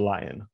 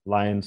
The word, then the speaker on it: Lion: which is David Lion. Lion spelled like the animal.